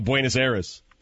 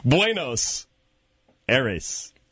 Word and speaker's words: Buenos Aires: Well, I need a naked man running around Buenos Aires. Did I say that right? – No. – Oh. – With Stugatz and Hockman. – Let's try it again. Buenos Aires.